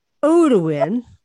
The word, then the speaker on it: Odeiwin: Odeiwin, which you've had to instruct me how to say, is spelled O-D-E-I-W-I-N.